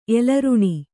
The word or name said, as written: ಎಲರುಣಿ